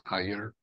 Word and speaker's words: Iyer: It's spelled as I-Y-E-R, and it's pronounced as higher, and just like higher, like I, like an I and a year, it's easy.